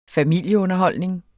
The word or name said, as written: familieunderholdning